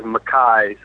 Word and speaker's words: MacKaye: and he he also corrected us as saying it was Mackay. So I guess that's the official pronunciation.